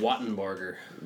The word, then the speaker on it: Wattenbarger: Wattenbarger. – Wattenbarger. Yep. Is that right? – That's it. – Damn, I got it right. – It's just like it's spelled.